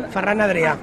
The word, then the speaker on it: Ferran Adrià: how do you pronounce his name, Fernan? – Ferran Adrià.